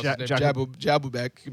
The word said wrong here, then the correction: Jakubek: I think it's Jakubek.